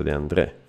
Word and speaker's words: De André: De André, ok? Cioè c'ha l'accento qua. Fabrizio De André, insomma.